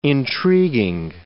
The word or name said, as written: intriguing